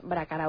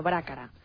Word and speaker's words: Brácara: No es Trastámara, sino Trastámara, así como no es Brácara Augusta, sino Brácara. Por lo demás, te felicito y especialmente por el fichaje de Pepín las noches de los martes.